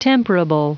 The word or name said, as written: temperable